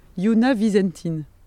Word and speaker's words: Yuna Vizentin: Yuna Vizentin, c'est comme ça qu'on prononce son nom.